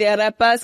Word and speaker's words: arepa: I just tried the arepa, so I understand the arepa power now.